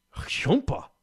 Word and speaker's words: Jhumpa: How else would you pronounce J-H-U-M-P-A? – I don't know. I would try. – Humpa.